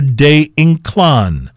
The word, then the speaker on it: Ynclan: At number 6, the La Flor de Inclan. That's D-E. And then Y-N-C-L-A-N, De Inclan, I believe I'm pronouncing it correctly.